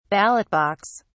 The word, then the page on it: ballot box: British English